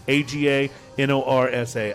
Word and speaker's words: Aganorsa: A G A N O R S A. Aganorsa Leaf.